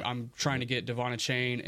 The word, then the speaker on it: Achane: It's Achane. He changed it earlier in the year. It was Achane initially. The NFL put out their whole pronunciation guide. It was a whole thing during the draft, and then he went off for like 200 yards in a game this year, and he's like, oh yeah, by the way, my name is pronounced Achane.